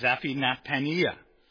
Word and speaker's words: Zaphnath-Paneah: That's how you say it, Zaphnath-Paneah.